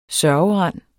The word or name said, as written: sørgerand